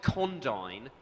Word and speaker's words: Condign: It's called Project Condign, C-O-N-D-I-G-N.